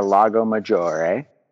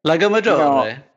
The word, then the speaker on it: Lago Maggiore: And I'm going to try and thread the needle in terms of pronunciation between correctness and being pretentious and sounding like shit. But it's Pino sulla sponda del Lago Maggiore. – Lago Maggiore. You know, Lago Maggiore. Lago Maggiore is what German people call it.